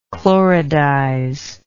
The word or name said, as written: chloridize